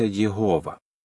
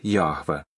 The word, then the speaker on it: Єгова: Українською це ім'я зазвичай вимовляється Єгова. Дехто вимовляє його як Яхве.